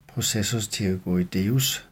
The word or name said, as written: processus pterygoideus